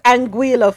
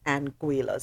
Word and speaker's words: Anguilla: So it's Anguilla, folks, even though it's spelled A-N-G-U-I-L-L-I-A. It's Anguilla. So it has a G-W sound.